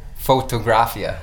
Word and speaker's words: Fotografia: And not only did he record, engineer, produce, and master, he's also DNA Media is linked with Bravo Photographa. I said that wrong. Anyway, Bravo Fotografia, I believe.